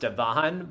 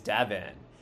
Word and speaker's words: Devon: And yeah, I know it looks like it's pronounced Devon, but it really is Devon.